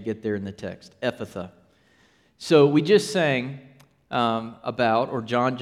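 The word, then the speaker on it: Ephphatha: That word is Aramaic, and it's not easy to pronounce because it's got PH in there twice. Do you see that? It's Ephatha. Ephatha.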